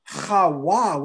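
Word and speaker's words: Chawa (חַוָּה): The Bible calls her Chawa with a W, which means to speak life.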